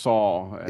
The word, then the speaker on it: Sol: I think it's pronounced Saul. – In my head, it's Sol, though. So I'm going to change now. – Sol is the name of son. It's spelled the same way.